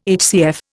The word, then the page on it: HCF: Indian